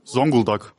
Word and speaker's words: Zonguldak: Ich komme aus Zonguldak. 6-7. – Woher, warte? – Zonguldak.